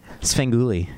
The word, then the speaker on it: Svengoolie: Svengoolie.